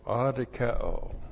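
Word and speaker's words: adikeo (ἀδικέω): A-D-I-K-E long O, adikeo.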